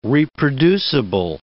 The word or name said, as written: reproducible